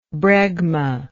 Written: bregmate

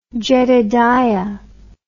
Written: Jedediah

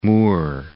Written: Mur

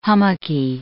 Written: hummocky

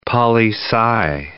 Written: poli-sci

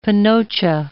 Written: panocha

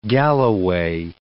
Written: Galloway

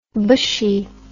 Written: bushie

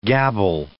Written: gabble

